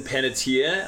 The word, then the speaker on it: Panettiere: And it starred Hayden Panettiere.